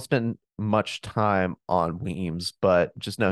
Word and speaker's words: Wemyss: And at the same time, he sent Major James Weems. It's spelled W-E-M-Y-S-S, but it's pronounced Weems.